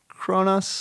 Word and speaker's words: Cronos: I think it's spelled Cronos, but I think it's pronounced Cronus.